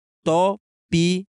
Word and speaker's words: Topic: Topic, porra. Da Asia Motors. Se pronuncia Topic.